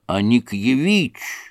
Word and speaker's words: Аникьевич: — Не Аникин, а Аникьевич, — сказал царь с ударением на последнем слоге.